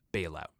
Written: Bailout

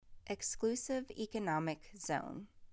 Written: Exclusive Economic Zone

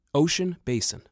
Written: Ocean Basin

